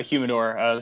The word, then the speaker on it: humidor: Well, these were a little, my dad didn't keep them in a humidor? Am I saying it right? – Correct. – Yes. – Yeah, a humidor.